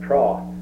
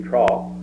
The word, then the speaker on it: trough: as a boy, we pronounce that trough, T-R-O-T-H. I've learned that it should be trough, but...